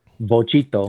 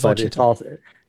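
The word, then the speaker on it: Vochito: It's actually spelled with a V. Bochito. – Oh, it's Bochito. – But it's just, it also sounds like Bochito.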